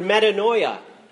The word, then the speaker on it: metanoia: In the New Testament, we find the word, the Greek word metanoia, which if you're paying attention, sounds like the word paranoia.